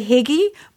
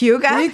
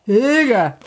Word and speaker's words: Hygge: You know that Scandinavian word, Hygge, that you want to say Higgy, but it's Hygge? – Hygge.